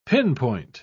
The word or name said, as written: pinpoint